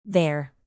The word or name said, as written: their